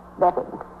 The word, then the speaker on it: Beavan: I spell it B-E-A-V-A-N, but I pronounce it Beaven.